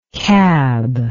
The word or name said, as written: kab